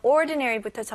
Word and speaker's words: ordinary: 네, 첫 번째 단어 ordinary부터 정리해 드릴게요. 네, 평범한이라는 뜻을 가지고 있고요. 발음 연습해 볼게요. ordinary ordinary ordinary. 네, 강세가 어디 들어갈까요? 네, 첫 번째 음절이죠. ordinary, ordinary.